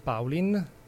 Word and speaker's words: Paulin: Sì, il disco si chiama Paulin, in questo caso l'accento lo mise sulla A,